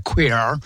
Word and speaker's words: queer: Alors, ce mot queer, moi je prononce en anglais, tu as vu, je fais classe quand même, un hein, queer.